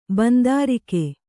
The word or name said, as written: ಬಂದಾರಿಕೆ